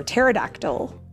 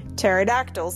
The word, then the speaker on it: pterodactyl: It is O-U-I. Can you imagine? Now, P is for pterodactyl. Uh-huh. Now, why do you suppose that is? Any idea? – The P is silent.